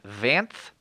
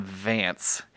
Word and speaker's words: Vanth: And then a guy named Vanth Dreadstar. And that is not me mispronouncing the name Vance. His name is V-A-N-T-H Dreadstar, which is so distracting to me, but yeah.